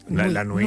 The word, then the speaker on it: nuit: Oui. Nuit. La nuit. – Nuit. La nuit. – Nuit, muy bien. O sea, huit, nuit. Perfecto.